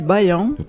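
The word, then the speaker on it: Baião: Bayao, je ne sais pas si je le dis bien. – Ça se dit Bayan, comme Bayan de Bayé.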